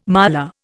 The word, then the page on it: miler: Indian